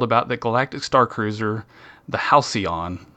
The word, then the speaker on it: Halcyon: I've been struggling with making sure that I say Halcyon correctly. So I'm proud. I was like, am I going to say Halcyon? But I said Halcyon. – That's usually how I say it.